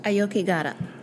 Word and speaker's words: Aokigahara: Okogare? The mountain in Japan? – Aokigahara. – Aokigahara, the Sea of Trees.